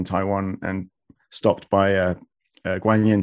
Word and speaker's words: Guanyin: And you mentioned Guanyin again. I noticed that you pronounce Guanyin rather than Quanyin.